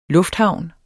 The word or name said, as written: lufthavn